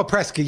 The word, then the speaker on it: Prescott: Beautiful Prescott. You said it right. Prescott. When I first got the Sedona, I used to say Prescott, and they looked at me like I was crazy.